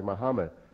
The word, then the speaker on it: Muhammad: So this name is not pronounced like that. It should be pronounced Muhammad.